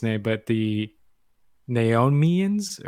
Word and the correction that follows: Nemoidians: or I'm sorry, I butchered that. – I believe it's Nemoidians. – Nemoidians, yes.